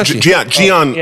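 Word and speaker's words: Jian: Gian. Okay, that's how you say his name.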